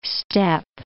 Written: steppe

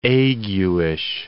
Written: aguish

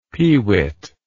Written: pewit

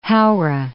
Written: Haora

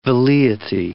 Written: velleity

